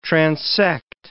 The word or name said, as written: transect